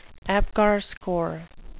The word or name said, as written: Apgar score